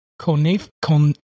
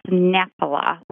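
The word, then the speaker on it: Knoephla: It's like a konefal? It's a creamy dumpling and potato soup. – Soup. Yeah, that is pronounced nephila. The K is silent, and it's called nephila soup.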